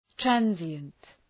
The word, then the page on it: transient: ‘trænzıənt; trænʃənt